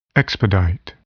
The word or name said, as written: expedite